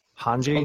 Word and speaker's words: Hange: Yep. Hanche. Mm-hmm. I think that's how it's pronounced. Hange. Hanji, okay. Whatever.